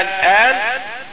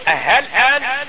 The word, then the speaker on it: الآن: الآن، هذا هو الإبدال مع الإشباع، والتسهيل الآن، ولا ننطق هاء كاملة، يعني ما نقول مثلا هل الآن، هذا خطأ، إنما هي ننطقها بين الهمزة وبين الهاء.